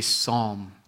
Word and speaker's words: Psalm: It's spelled P-S-A-L-M. Maybe you may have pronounced it before. Psalm. That's not how you pronounce it. The P is silent.